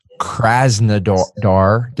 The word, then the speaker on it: Krasnodar: Krasnodar.